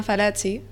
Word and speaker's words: Fallaci: Vale, el mío de agosto es de una periodista italiana que se llama Oriana Fallaci. O Fallaci, no sé cómo se pronuncia. Muy bien, bueno, Marina, tú que estás en el Duolingo italiano, ni idea. No, tu pronunciación ha sido perfecta.